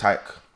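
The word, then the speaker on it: Tayc: Taysi, or Tace, whatever his name is. – It's Tyke. – Is that how you say it? – It's Tyke, yeah. – Oh, it's Tyke. – Yeah, he's amazing. – Taysi. – I thought it was Taysi this whole time, so fair enough.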